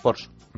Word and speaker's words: Porsche: Porsche.